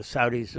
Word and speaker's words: Saudis: However, what is happening here is that the Saudis, you will notice, are not, or Saudis, as we pronounce it, are not signing on to this.